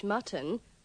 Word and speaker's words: mutton: Remember to pronounce the O in mutton with the schwa. – If you want to have Polish mutton, we'll need some mustard as well.